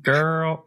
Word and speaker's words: GURL: So it is spelled G-U-R-L. because we're fun. – Girl.